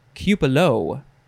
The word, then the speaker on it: cupola: Next is cupola, or cupolo, even though it's spelled C-U-P-O-L-A.